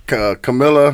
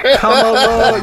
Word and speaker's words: Kamala: Because they don't know if it's Camilla. – Camilla. Kamala. Yeah.